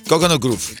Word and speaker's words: Coconut Grove: Grove, ¿cómo se pronuncia? Coconut Grove. – Coconut Grove. – Grove. Grove. Eso.